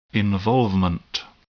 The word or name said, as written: involvement